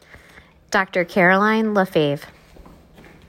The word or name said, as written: Caroline LaFave